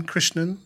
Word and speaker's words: Krishnan: Like Krishnan. N-A-N, Krishnan. That's how you say it.